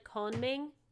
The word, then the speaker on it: Kongming: But the, in the anime, I've noticed this, they don't pronounce the first G. It's like Konming. It's not like Kongming.